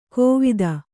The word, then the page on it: ಕೋವಿದ: kōvida